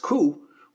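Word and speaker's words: ku (九): Similarly, the number 9 is also unlucky as it's pronounced ku, which also sounds like the word for agony.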